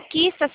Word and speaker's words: Qista: É, o Quista se escreve QISTA.